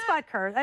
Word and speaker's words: Butker: It is Butker. I said it right.